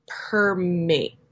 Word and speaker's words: permeate: goods from retaliatory tariffs, and they're hoping to really get into the whey permeate. That's how you say it.